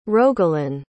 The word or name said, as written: rogelyn